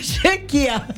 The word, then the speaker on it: Chequia: Ay, está un poquito difícil, pero se escribe C-Z-E-C-H-I-A. – ¿Y cómo se pronuncia? – Chequia.